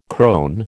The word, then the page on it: crone: American English